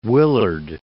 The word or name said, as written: Willard